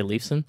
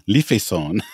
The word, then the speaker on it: Lifeson: I always feel like it's something fancier than that, and I always want to say Leafson. – So Alex Lifeson. – Lifeson.